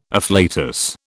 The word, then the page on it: afflatus: British English